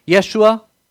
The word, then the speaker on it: Yeshua: It's actually, in Hebrew, it's pronounced Yeshua.